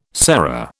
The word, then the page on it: sera: British English